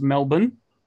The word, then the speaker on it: Melbourne: For you Americans, it's pronounced Melbourne.